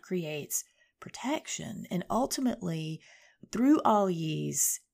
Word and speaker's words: Algiz: We're looking at Algiz this week, or Algiz, as some people pronounce it.